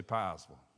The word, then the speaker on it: Piesville: You don't say it like it's spelled. You say Piesville. I know. I've been around here long enough. P-I-E-S-V-I-L-L-E, Piesville.